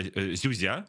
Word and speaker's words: Зюзя: Ну и они сделали репортаж из села Зюзя, именно так ставится ударение, которое типично российское загибающееся село.